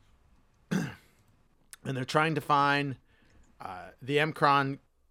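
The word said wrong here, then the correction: M'Kraan: I never knew how to say it. I said Mcron, but it's pronounced M apostrophe K R A A N. The uh, Macron crystal.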